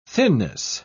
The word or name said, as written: thinness